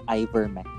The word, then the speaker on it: ivermectin: Sige, for those listeners that we have right now na hindi alam, what is ivermectin? Alam ivermectin. Tama ba yung pagka-pronounce ko? – Yes. – Yeah, kasi akala ko ivermectin, parang Bon Iver, di ba?